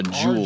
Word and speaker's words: Juul: J-E-W-L. – No, this is the way they spell their company. Just like concrete spelled with a K. This one's spelled J-U-U-L.